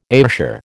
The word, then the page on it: Ayrshire: American English